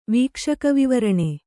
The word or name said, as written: ವೀಕ್ಷಕವಿವರಣೆ